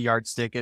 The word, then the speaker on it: Yardstiq: So if anyone goes over to Yardstick, it's Yard, and then Stick is spelled STIQ.